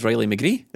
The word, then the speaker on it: McGree: You had a go at me for getting his name wrong and you just done it there. – I said Riley McGree. – Yeah, he said McGee.